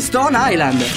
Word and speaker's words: Stone Island: Questi jeans della Stone Islands. – Stone Island. – Perché scrivono come si legge? – Si chiama così, Stone Island.